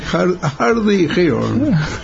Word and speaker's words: Hardy Heron: Hardy Hero, ¿cómo se pronuncia? – No sé, nunca me acuerdo el nombre, ¿cómo es? – Hardy Hero. – Sí, creo que es la garza vamos. Era una cosa así, la garza dura o no sé, es que tiene una traducción muy así. Mejor es como tú lo pronuncias. A ver, vamos a escucharlo otra vez. – Hardy Heron.